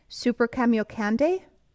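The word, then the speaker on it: Super-Kamiokande: Andrew, you're going to have to correct my pronunciation in this. Super Cameo Candy? – Yeah, that's how I pronounce it.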